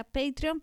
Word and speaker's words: Patreon: Sí, vamos a aclarar, la página se pronuncia Patreon, pero se escribe Patreon. – Sí, sí, sí. – Como patrón, pero con AE.